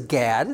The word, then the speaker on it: Gad: Now, I know it says Gad, and that's fine if you want to pronounce it like that. We're not saying it's G-O-D. G-A-D, remember I say the A is pronounced as Ah.